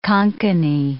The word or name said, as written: Konkani